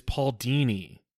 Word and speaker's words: Paul Dini: I just realized you said that one of Paul Dini's favorite characters was Atana, and he literally married a magician, and his name is Paul Dini. like Houdini, Paul Dini.